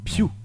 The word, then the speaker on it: Pugh: Tu prononces pas la fin. En fait. – Ouais. – Pugh. Florence Pugh. D'accord. – C'est pas Pew Marmaille, c'est pas Florence Pew.